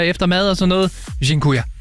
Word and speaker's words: Dziękuję: Og en af dem, som jeg har brugt rigtig meget, det er det, der hedder, undskyld min udtale, Dziękuję.